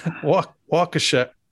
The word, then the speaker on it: Waukesha: Waukesha, I think that's it. No, that's right. We were saying, in Iowa in particular, we're saying Waukesha. It's Waukesha.